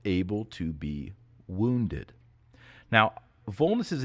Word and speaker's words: vulnus: Now, the Latin word of the week is vulnus. Vulnus, or if you want to use classical pronunciation, vulnus.